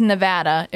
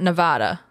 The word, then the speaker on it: Nevada: And apparently, it is Nevada. It's not Nevada.